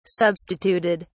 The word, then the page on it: substituted: British English